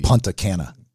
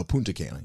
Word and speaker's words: Punta Cana: Punta Cana. – Oh, Punta Cana, yeah.